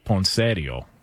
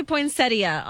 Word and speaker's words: poinsettia: Well, the in-laws would call it poinsettio. – Oh, gosh. Okay. – What up? You know what I'm saying? – Oh, God. – Can we get the proper pronunciation on this? – It does say poinsettia online.